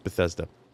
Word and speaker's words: Bethesda: It's not a long E. It's a short E. Bethesda. So, what are we Bethesing today?